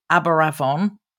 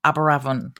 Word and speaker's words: Aberavon: So the first one we are going to Aberavon oh gosh, I've said that wrong, Aberavon Surf Lifesaving Club, which is in Port Talbot in South Wales.